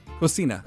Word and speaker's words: Cocina: Let me make sure I say that right. Let me hit the button here. Is it... – Cocina. – Cocina.